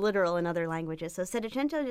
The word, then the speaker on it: Settecento: Settecento.